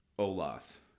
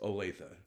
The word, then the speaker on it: Olathe: Olathe. – Oh, okay. – It's a Native American name. You sound it out phonetically, Olathe. – Okay. – You know, it's not Olathe.